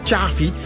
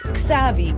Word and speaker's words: Xavi: Want jij zegt Xavi. – Ik zeg Xavi. – Dus met T-J eigenlijk. Of is het jij zegt Xavi. Ja, echt zo'n Hollandse. Ja. Hollandser kan niet. – Hollands, hè? Klopt, ja. – Maar het is Xavi.